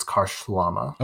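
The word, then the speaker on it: Karşılama: But the S and the I are a Turkish. Oh, okay. – They have symbols. – It looks like Karshalama, but it's Karshalama.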